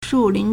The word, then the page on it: 树林: 樹林; shùlín